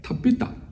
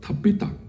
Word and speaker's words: Tabitha: 多家呢是个亚兰文，这个字本身是个亚兰文，发音就是 Tabitha，这个亚兰文就希伯来文了，就是其中一个分支了，叫做 Tabitha。